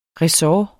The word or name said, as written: ressort